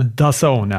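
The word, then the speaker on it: DAZN: Är det så man uttalar det? Dazone? Dazone, ja.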